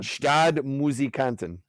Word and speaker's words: Stadtmusikanten: And I'm going to butcher the word... Stadtmusikanten. Does that sound right? – Yeah. Stadtmusikanten.